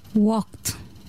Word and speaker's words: walked: Walk, qui se termine par un K, sera prononcé walked.